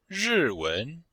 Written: ri4wen2

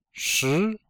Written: shi2

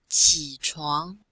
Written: qi3chuang2

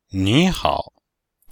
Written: nihao

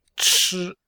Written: chi1

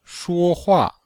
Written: shuo1hua4

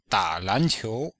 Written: da3lan2qiu2

